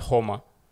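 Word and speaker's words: Joma: В Испании преимущественно все говорят Хома.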